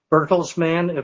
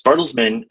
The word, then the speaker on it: Bertelsmann: Can you just give us a brief description of Bertelsmann, if I pronounced that correctly? – Absolutely. Yes, Bertelsmann is a large media services and education company that operates in over 50 countries around the world.